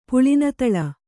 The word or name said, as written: ಪುಳಿನತಳ